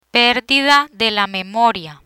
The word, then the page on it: perdida de la memoria: Spanish